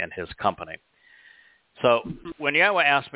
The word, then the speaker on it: Yahweh: His name is pronounced Yahweh. It's exceedingly easy to figure out how to pronounce it. In fact, if you can say Torah, you can say Yahweh.